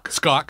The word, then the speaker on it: Skok: Do you know David Skok from The Logic? – Yeah, I call them Skok, but I think it's Skok. – Skok. – Skok like a sock.